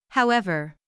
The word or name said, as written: however